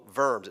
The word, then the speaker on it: Worms: They call it a diet in the city called Worms. It's spelled like worms, but pronounced Worms, okay?